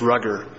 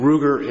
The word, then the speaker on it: Ruger: As an initial fact, it's strange that Mr. Zermiller, who's accused of mispronouncing Ruger as Rugger, is being investigator suspected of stealing this gun, yet he knew the exact title of the gun.